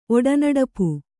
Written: ಒಡನಡಪು

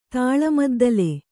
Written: ತಾಳಮದ್ದಲೆ

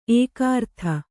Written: ಏಕಾರತಿ

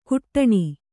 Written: ಕುಟ್ಟಣಿ

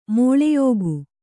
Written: ಮಿಳಿಯಿಕ್ಕು

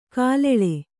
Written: ಕಲ್ಲಾಳ

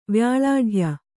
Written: ವ್ಯಾಳಾಢ್ಯ

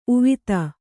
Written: ಉವಿತ

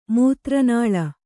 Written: ಮೂತ್ರನಾಳ

